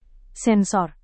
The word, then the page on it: sensor: indonesian